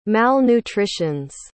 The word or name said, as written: malnutritions